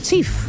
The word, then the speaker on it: Cheaf: Una de ellas, esta me encanta, es Chief, que ofrece packs sorpresa.